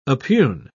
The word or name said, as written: oppugn